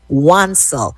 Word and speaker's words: Wansel: Dexter's last name is pronounced Wansel. Dexter Wansel.